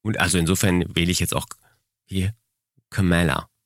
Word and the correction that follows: Kamala: Nee, ich habe schon wieder falsch ausgesprochen. – Kamala. – Kamala. Kamala. So ein bisschen wie Camelot, ne? Daran kann ich mich orientieren.